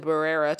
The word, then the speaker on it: birria: And Megan actually made me the birria tacos.